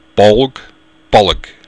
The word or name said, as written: bolg